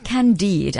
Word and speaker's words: Candide: An app called Candide. Am I pronouncing it correctly? Yes, they're nodding.